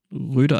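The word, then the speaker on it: Røde: von Ro Rode oder wie spricht man das eigentlich aus? Röde? Rode? Ja, ja, also so ein Ding halt und ähm, Röde. Röde eigentlich, ne? Das ist ja ein Ö.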